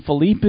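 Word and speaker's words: Phillippe: Ryan Philippe, is that how you say it? – Yeah.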